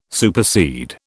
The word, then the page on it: supersede: British English